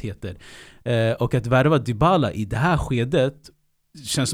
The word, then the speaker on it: Dybala: Nej, de här svenskarna, kommentatorerna säger alltid Dybala, Dybala. Dybala är mycket rättare eh, uttal enligt mig.